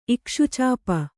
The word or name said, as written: ಇಕ್ಷುಚಾಪ